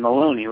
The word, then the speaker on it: Maloney: It's Maloney with an L.